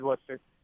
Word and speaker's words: Worcester: Am I saying that right? It is Worcester, right? – Yeah, it is Worcester.